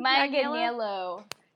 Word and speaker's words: Manganiello: I want to say, one, I think that what I thought was really interesting is that Joe Manganiello, is that how you say his name? – Manganiello.